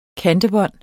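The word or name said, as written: KANTEBÅND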